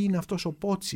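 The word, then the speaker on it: Pozzi: Λοιπόν αυτός ο Πότσι είναι πολύ ενδιαφέρουσα μορφή ε, κατάγεται από μια οικογένεια Ιταλών που μετήκησαν στην Γαλλία ε, και κράτησε το όνομά του με την Ιταλική του προφορά, ενώ ο πατέρας του είχε κάνει ποζή, ποζί, ποζί, ποτσί, κάπως έτσι.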